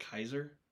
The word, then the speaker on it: Caesar: Did you know Caesar was originally pronounced like Kaiser?